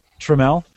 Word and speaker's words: Tramiel: How do you pronounce his last name? Jack Tramiel. – Tramiel. – Yeah, Tramiel. 83,